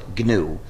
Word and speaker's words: GNU: In any case, when you're talking about our operating system, please do not follow the dictionary. Please pronounce it GNU. If you talk about the new operating system, you'll get people very confused.